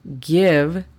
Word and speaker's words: give: La pronunciación fonética de este sería F-L-A-I, fly. El tercer verbo es give. Give significa dar. La pronunciación fonética de este sería G-I-E-V, give.